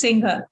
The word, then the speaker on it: Singhal: So, is it Singhal? Am I saying that correctly? – Yes, it's Singhal.